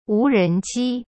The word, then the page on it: 无人机: wu ren ji